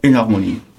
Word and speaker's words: énharmonie: D'abord, est-ce que ça se prononce énharmonie? – Oui, ça se prononce comme ça, énharmonie.